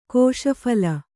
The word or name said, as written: ಕೋಶಫಲ